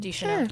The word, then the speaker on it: Deschanel: Deschanel.